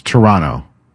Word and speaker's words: Toronto: They get a win over Toronto. And again. – No. Try that again. It's Toronto. You don't pronounce the second T.